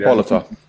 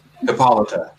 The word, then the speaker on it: Hippolyta: I'm going to say Hippolyta. I'm going to screw up her mother's name. Hippolyta. – Hippolyta. – Hippolyta.